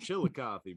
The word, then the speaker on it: Chillicothe: It, so how it's spelt is Chillicoth, but they're just like it's called Chilla Coffee, bro.